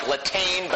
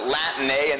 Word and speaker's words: Latané: It looks like Latane, but you pronounce it Latané, and the B stands for Bib.